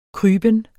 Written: kryben

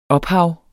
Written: ophav

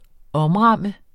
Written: OMRAMMEDE

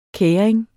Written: kæring

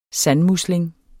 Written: sandmusling

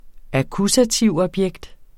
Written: akkusativobjekt

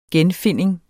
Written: genfinding

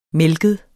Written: mælket

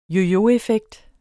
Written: yoyoeffekt

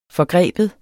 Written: forgribe

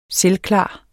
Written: selvklar

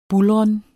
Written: buldren

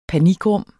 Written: panikrum

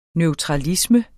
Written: neutralisme

